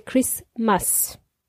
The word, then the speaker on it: Christmas: By the way, Christmas is pronounced with a silent T. We don't pronounce the T in that word Christmas. We say Christmas.